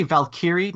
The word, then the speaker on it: Valkyrie: Valkyrie is probably how most people would say it. I think the original word is Valkyr.